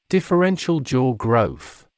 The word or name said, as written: differential jaw growth